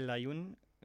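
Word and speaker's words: Layún: Miguel Layun so spricht man den richtig aus.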